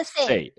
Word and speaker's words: Say: Entonces tuvimos un debate como de 40 minutos de cómo se pronuncia la, ley de Say, ¿no? Porque la ley de Say viene de Jean-Baptiste, tal vez, eh, em, ¿me corriges? – Jean-Baptiste Say. exacto. Sí, Jean-Baptiste Say.